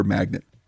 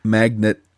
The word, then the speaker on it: magnate: But according to the Cambridge Dictionary, the English pronunciation, that is to say people who live in England, magnet is acceptable. – Magnet. – For M-A-G-N-A-T-E.